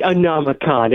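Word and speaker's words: Anomacon: Did I say that right? – That's right. Anomicon.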